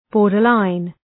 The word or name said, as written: borderline